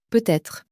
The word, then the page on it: peut-etre: french